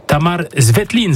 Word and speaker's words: Tamar Zvetlin: Le temps pour nous de découvrir l'une des nouvelles têtes de l'effectif, le Slovène Tamar Zvetlin, c'est comme ça que ça se prononce.